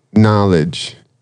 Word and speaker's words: knowledge: So, knowledge, right? You got a K there you don't pronounce.